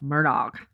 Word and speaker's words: Murdaugh: I think we ought to say it like from the words of Alec himself, Murdaugh and Papa.